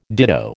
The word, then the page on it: ditto: American English